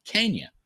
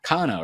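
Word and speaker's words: Canha: And I say Cagna because he was on Sunday Night Baseball with a microphone and he said his name is pronounced Cagna, not Cana or whatever they were saying.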